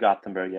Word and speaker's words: Gothenburg: So being from Gothenburg, and uh, am I pronouncing that right? Because I've never pronounced anything right the whole time I lived in Denmark. So. yeah, very good. It's Gothenburg, yeah, or like the Okay.